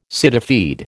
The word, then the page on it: cityfied: American English